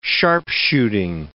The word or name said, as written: sharpshooting